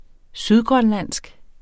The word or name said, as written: sydgrønlandsk